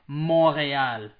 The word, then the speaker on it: Montreal: Yeah, I gotta say too, I'm French-American, so I gotta say it correctly. It's Montreal, ladies and gentlemen.